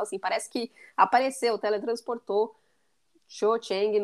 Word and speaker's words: Cho Chang: Eu chamo de Xoxeng, se você quiser corrigir minha pronúncia. – É, o Cho, Cho, né? Cho Chang.